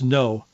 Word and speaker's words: Ngo: At the conclusion of a court hearing just wrapped up, this journalist, Andy Ngo, his name is spelled N-G-O, but it's pronounced No, was a multi-day civil trial against this Rose City Antifa.